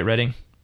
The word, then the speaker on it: Reading: Did I say that right? Reading? – Yes.